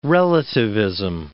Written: relativism

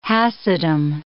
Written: Hasidim

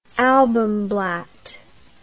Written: albumblatt

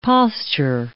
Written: posture

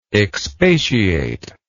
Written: expatiatory

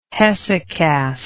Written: hesychast

